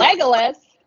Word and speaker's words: Legolas: Legolas? – That's it.